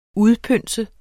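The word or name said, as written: UDPØNSE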